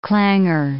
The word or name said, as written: clangour